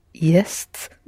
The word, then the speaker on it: Есть: So how do you pronounce the word? – Jest. – Da, jest, which means both. Both what? – To eat. – To eat and? – There is. – And there is, jest.